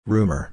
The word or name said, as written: rumor